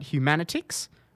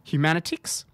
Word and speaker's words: Humanitix: website Humanitics, which is spelled Humanitix. Humanitics.